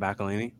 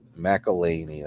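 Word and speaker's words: McElhenney: Rob McElhaney. – McElhaney, that's how it is.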